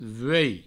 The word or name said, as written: vuei